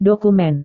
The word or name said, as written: dokumen